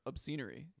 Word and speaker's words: Obscenery: I think it's pronounced Obscenery because it combines obscene and scenery.